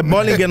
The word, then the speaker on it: Bollinger: Scusami un attimo, allora il Crystal meno il Bollinger mi piace molto a me. – Bollinger si pronuncia, Bollinger non sei neanche capace di parlare in francese, fenomeno.